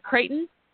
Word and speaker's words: Creighton: And also Senator, is it Creighton? – Yes. – Am I saying it right? Creighton, Republican Perfect.